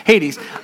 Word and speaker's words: Hades: It's actually two syllables. Okay, great. Hades.